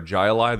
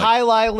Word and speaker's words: Hialeah: He had a beer called, I don't know how to pronounce it correctly, Hialeah or Jialeah, the old game with the hooks that people used to play. – It's called Hialeah. It's from that off-betting dog tracks.